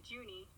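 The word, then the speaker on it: Juni: Judy Cortez, Jordy, I forget how to pronounce his name. – Judy, yeah. – Yeah. – It's actually Junie. – Junie, yeah, with an N, right.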